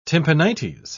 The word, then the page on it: tympanites: British English